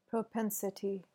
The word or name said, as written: propensity